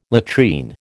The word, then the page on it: latrine: American English